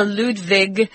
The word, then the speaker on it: Ludwig: In German, they don't pronounce W like W, it's V, V. So Ludwig, and they like a lot of sh.